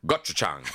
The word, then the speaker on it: gochujang: Okay, let's start with gochujang. – Yeah, that was perfect pronunciation, was it? – I believe.